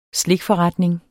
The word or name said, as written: slikforretning